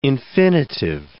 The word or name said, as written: infinitive